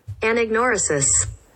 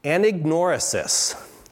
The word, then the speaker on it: anagnorisis: Anagnorisis. – Anagnorisis. I think of a dinosaur named Anna that's ignored.